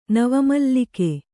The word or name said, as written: ನವಮಲ್ಲಿಕೆ